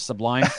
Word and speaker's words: Sublime: Subline. – Nice. – Like their peers. – Also, I like how you put the wrong emphasis on the wrong syllable with Sublime.